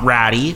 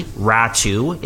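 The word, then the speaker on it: Räty: In exchange for Anthony Beauvillier, 25-year-old forward, Atu Ratu, not Ratty, Ratu is the correct pronunciation there, and a conditional 2023 first-round draft pick.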